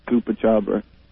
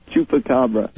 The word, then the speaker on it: chupacabra: I just taught you how to say that, and you said it wrong. Again. – chupacabra.